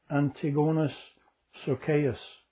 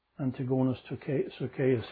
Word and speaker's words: Antigonus Socaeus: in the Roman way of pronouncing it, would be Antigonus Socaeus. Antigonus Socaeus.